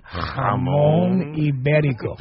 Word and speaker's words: Jamón Ibérico: Jamón Ibérico. – Yeah, I can't do that. – That's what it's like, $66 a pound. – Wait, say it again. How do you say it properly? – Jamón Ibérico.